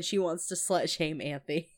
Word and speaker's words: Anthy: Anthy. It's like Anthony, but like missing a syllable.